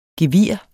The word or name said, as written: gevir